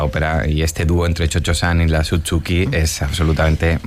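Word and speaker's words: Cio-Cio-San: Pero como claro, la pronunciación italiana como Chocho-san.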